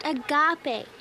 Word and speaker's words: agape: Now, that word you saw the other day is pronounced agape. It's a Greek word, and it means...